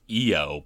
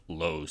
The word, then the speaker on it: Io: It's actually pronounced Io, but the word is spelled I-O, so it's Io Saturnalia, not Lo Saturnalia.